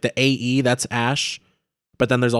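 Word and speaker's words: X Æ A-12: Well, so I think Elon was on like Joe Rogan Experience and was like, he was just like, yeah, it's pronounced Ash, but the numbers are for like a rocket and a plane that he really likes.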